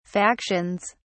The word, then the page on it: factions: British English